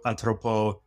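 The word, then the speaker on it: Atropos: And in the Greek mythology, it's Clotho, Atropo, and Lachesis. – Excellent Greek pronunciation there.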